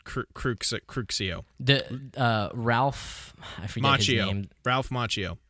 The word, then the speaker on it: Crucio: Crucia, I'm going to mispronounce that every time. – Crucio is the actual, like, how you cast it.